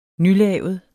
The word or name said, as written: nylavet